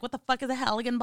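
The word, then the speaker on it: Halligan: He was bludgeoned in the face with a Heligan bar. Is that how you pronounce that? – Something like that.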